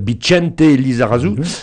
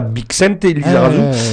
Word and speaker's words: Bixente Lizarazu: Ça s'écrit X-A-R-R-E. Mais comme dans le Pays basque, on prononce le X tché. Bicente Lizarazu et non pas Bixente Lizarazu.